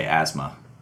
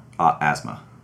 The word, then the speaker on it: Ozma: So, I saw a music video yesterday about a song called Spider-Man by a guy named DJ Ozma, O-Z-M-A-A. – Oh, that's DJ Asthma. – Hmm. – He pronounces it Asthma. – Well, in any case, I recommend the fans check it out. Spider-Man, DJ Ozma. – Asthma. – Right. – Spelled O-Z-M-A. – If you type in Asthma. – No, don't type in Asthma. Just say it Asthma. Say it correctly.